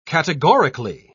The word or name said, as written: categorically